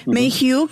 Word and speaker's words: Mayhew: Mayhew,